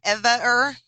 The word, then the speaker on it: Evaer: Dave got cut off because I had not activated my E V A E R, Evaer. How do we say this? Evaer. – Yeah.